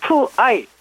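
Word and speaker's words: 푸아이 (父爱): 우리말 한자 모성애의 성자만 빼고 모에 그다음에 부성어의 성자만 부에 이렇게 갖다가 모성애 부성어를 쓰는데 발음은 무아이 푸아이 무아이 푸아이입니다.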